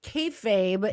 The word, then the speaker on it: kayfabe: That is thrown around to describe pro wrestling, and it's kayfabe, yes. – If I'm pronouncing it correctly.